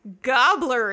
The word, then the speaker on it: Gabler: Which is pronounced Gobbler in Sweden.